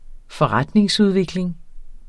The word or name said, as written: forretningsudvikling